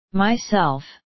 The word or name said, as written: myself